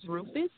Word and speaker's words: Rufus: And my last name is Rufus, RUFUS.